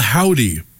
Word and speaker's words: Hovvdy: They're called Howdy, but it's spelled H-O-V-V-D-Y. So when you put those V's together, it's a W.